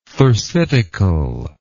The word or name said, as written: thersitical